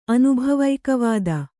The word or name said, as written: ಅನುಭವೈಕವಾದಿ